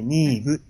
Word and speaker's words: Niamh: ni, nim, niamh って書いて、ニーブって発音したりとか。